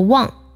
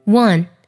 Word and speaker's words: one: 一这个单词，很多同学容易读作 one 或是 when，都是有问题的。这里呢，它的元音是小口啊的音，w 加鼻音，one one 要这么去读它。